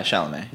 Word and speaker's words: Chalamet: Uh, I love uh, the the lead actor Chalamet. – So, yeah, Chalamet. – That's yeah. how you say his name.